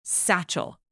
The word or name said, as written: satchel